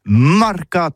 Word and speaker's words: marcat: Mais en gascogne toulousain, c'est prononcé sur les marcat avec un A.